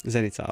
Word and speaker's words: Zenica: Nations League ikväll, alltså Sverige-Bosnien, Edin Džekos sista match spelas i Senica, vad det var? – Zenica, ja. – Zenica, ja, du får hjälpa mig med uttalet.